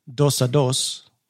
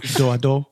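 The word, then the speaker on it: dos-à-dos: The term is a corruption of the original French term dos-a-dos. I think that's how to pronounce that. It's probably, since it's French, it's probably like, I'm probably pronouncing way too many of those consonants. It's probably like do-a-do.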